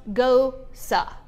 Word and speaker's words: Gose: It's spelled G-O-S-E. But I kept saying gose, gose, gose. Some people call it a gose. But I looked it up for the dictionary, and I'm studying for my Cicerone, and it's gosa. I don't know why they spell it the way they do, but it's pronounced gosa.